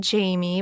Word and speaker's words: Jaime: But if you want to reach me personally, which I would love, find me on Instagram at, it's my first name, Jaime, but it's spelled a little differently. It's J-A-I-M-E, Schmidt, S-C-H-M-I-D-T, 22, which is my lucky number.